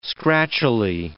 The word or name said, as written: scratchily